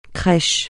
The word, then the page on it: crèche: French